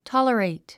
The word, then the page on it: tolerate: tάlərèit; トォラレイト